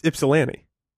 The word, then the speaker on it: Ypsilanti: Y-P-S-I? L-A-N-T-I? – This is Ypsilanti. – Ypsilanti? Is that how you say that? – Yeah.